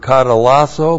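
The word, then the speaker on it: katallasso: And this is the aorist passive indicative of the verb katalasso plus the dative singular of theos with the definite article.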